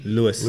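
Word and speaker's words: Luis: Louis. – Louis. Well, it's L-U-I-S.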